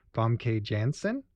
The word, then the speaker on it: Famke Janssen: Famke Janssen as Jean Grey.